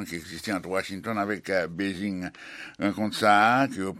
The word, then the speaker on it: Beijing: On a simplement précisé pour l'auditeur, si autant de Serge Rodriguez dit Beijing, c'est parce qu'il a prononcé ville Pékin en chinois.